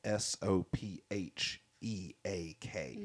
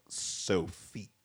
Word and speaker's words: Sopheak: But just so everybody knows, her name is spelled S O P H E A K. – Mm-hmm. Sopheak. Yep. – Um, so phonetically, it looks like Sophique.